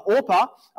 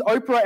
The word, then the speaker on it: Orpah: Moves back to her hometown of Bethlehem, telling Ruth and her other daughter, Orpah, which is spelled incidentally very closely to Oprah. So I keep on mispronouncing that. I didn't this time. Thought I'd mention it anyway. Leaves Oprah and Ruth back in Moab, but Ruth does not take that.